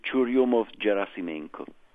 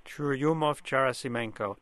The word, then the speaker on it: Churyumov-Gerasimenko: Well, we pronounce it Churyumov-Gerasimenko. – Churyumov-Gerasimenko. – Right.